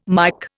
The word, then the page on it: Mike: Indian